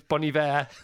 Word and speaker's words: Bon Iver: Oh, it's Bon Iver. That's why. But it's spelled Bon Iver. There's a place called Iver off the A40, and it's spelled like that. And Bon is a separate word. So I'm going for Bon Iver. It doesn't work.